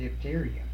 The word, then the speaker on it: diphtheria: Well, that would be a serious matter on the diphtheria.